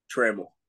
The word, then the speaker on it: Trammell: Trammell? – Trammell. – Trammell. All right, cool. Latricia Trammell. – Trammell. – Trammell. – Like camel, but Trammell.